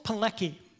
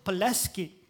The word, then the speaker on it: Pilecki: In September of 1940, Witold Pilecki, that's how I'm going to pronounce it. It's a Polish name, W-I-T-O-L-D, Pilecki, P-I-L-E-C-K-I. I think in Poland they'd say Witold Pileski, or an approximation.